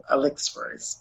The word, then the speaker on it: Elixiris: It's hard to pronounce, too, Elixiris, but that's how you pronounce it.